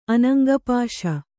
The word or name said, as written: ಅನಂಗಪಾಶ